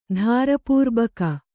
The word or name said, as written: ಧಾರಪೂರ್ಬಕ